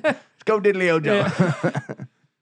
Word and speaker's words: Orgeron: It is fucking Orgeron. – Yeah. – O-R-G-E-R-O-N. O-R-G. Okay.